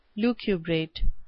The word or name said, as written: lucubrate